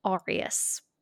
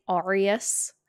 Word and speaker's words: Arias: So I say Arias, but I know that Americans say Arias. Don't come for me, like, please. – Sounds like areola, and I don't like it. – It's spelt A-R-I-A-S. – Arias. – Arias for the other people, but we're British, so I'm going to say Arias, and you're gonna have to get the fuck over it.